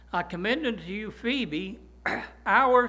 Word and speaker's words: Phoebe: Now, some people spell it P-H-E-B-E. Some people put an O in it. But either way, it's pronounced the same, Phoebe.